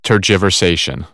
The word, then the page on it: tergiversation: American English; ɝː